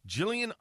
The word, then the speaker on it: Gilligan's: It's not Gilligan's. It's spelt Gilligan, but it's actually pronounced Jillian. Jillian's Island Ice Cream Shop.